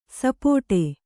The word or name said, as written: ಸಪಾಟಿ